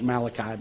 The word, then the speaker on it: Malachi: Okay, the proper etiquette of saying Malachi is Malachi, by the way. A lot of people pronounce it, they're like, Malachi? Malachi.